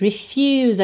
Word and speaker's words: refuse: Refuse. Atenção à pronúncia dessa letra U, tá? Que ela é you.